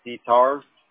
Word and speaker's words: Petar: It's Pitar. – Okay. Pitar. How do you spell that? – P-E-T-A-R.